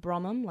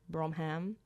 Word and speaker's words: Bromham: But that band's called Bromham, like Bromham. – Oh, yeah. – We get some interesting ways of pronouncing it.